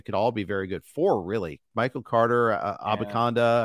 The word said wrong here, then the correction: Abanikanda: Abanikanda, excuse me. I always get that. I always mispronounce his name.